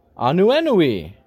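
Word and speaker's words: Ānuenue: Like P-O-N-I. Pony. Mahina means moon. Hoku means star. Anuenui. Anuenui. I said it right. That means rainbow.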